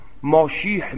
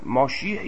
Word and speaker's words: ماشیح: تا آمدن منجی، چون هم قائل به منجی هستن، که اونا منجی رو ماشیح میدونن، ماشیح که همون مسیحیه که چون عبری در زبان عبری سین رو شین تلفظ میکنن، ماشیح یا ماسیح